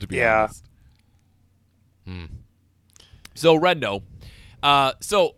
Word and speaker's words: Renault: This is a 1912 Renault, I believe is how you pronounce that. I know because I went to YouTube and typed, how do I pronounce Renault? – And it told me. – That's how James Cameron pronounces it.